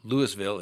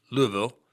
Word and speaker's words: Louisville: Maybe there's something I'm missing here, but perhaps the Louisville, Kentucky Police Department, and hopefully I pronounced that, it's not Louisville, it's Louisville, will